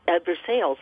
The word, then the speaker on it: Versailles: And they talked their way onto a steamboat, the Versailles, Versailles, as it is pronounced in Kentucky, and they to spend a night and a day pretending to be free people on that steamboat until they landed in Cincinnati.